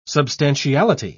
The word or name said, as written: substantiality